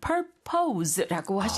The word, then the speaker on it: purpose: 이게 P-U-R-P-O-S-E로 쓰게 되고 발음은? – Purpose. – Purpose. – 액센트가 앞에 있죠. – 그렇습니다. 그래서 purpose라고 하지 purpose가 아니라는 거.